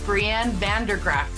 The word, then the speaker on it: Brieanne Van De Graaff: Hello, and welcome to Share Your Hotness podcast with my guest, Brianne Van De Graaff.